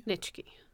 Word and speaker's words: Nitschke: Nitschke.